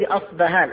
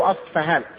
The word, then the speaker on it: أصفهان: الدجال يتبعهم 70,000 من يهود أصبهان، أو أصفهان تنطق بالب اللي أشبه بالفي الإنجليزية أو بالفاء.